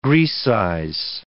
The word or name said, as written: grecize